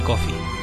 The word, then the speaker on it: Coffey: Pero si hay alguien que destaque sobre el resto es el inolvidable Michael Clarke Duncan en la piel del preso John Coffey, como El Café pero se escribe diferente.